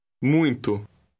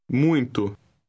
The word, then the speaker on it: muito: Muito. Muito. – Note that the letters UI are pronounced UI.